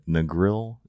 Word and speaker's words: Negril: I looked up how to say it Negril, Jamaica, oh.